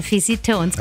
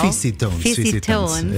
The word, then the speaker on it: Fizzytones: Habe ich es richtig gesagt? – Ja, ja. La Pix and the Fizzytones, genau. – Fizzytones.